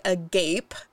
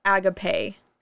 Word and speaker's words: agape: This one's spelled like agape, but it's pronounced agape.